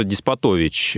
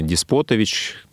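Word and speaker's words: Деспотович: Джорджа Диспотович э, из Оренбурга или Диспотович, по-разному ставят ударение в его фамилии.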